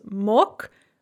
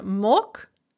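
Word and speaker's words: mok (мог): So note that on mok, the g is pronounced like a ka because of word final devoicing, but we add the feminine ending ana magla, the g is pronounced as a g once again.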